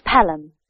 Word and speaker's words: Pelham: My name's Pelham. And my number... – Oh, can you spell that, please? – Yes. That's P-E-L-H-A-M.